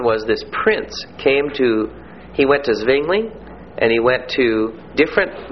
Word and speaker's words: Zwingli: Now we move to the Swiss Reformation, and this will associate Ulrich Zwingli, U-L-R-I-C-H, with Zurich, a city in Switzerland. Z-W-I-N-G-L-I. It's pronounced in English like it is spelled with a T-S-V. Zwingli. That's the correct English pronunciation.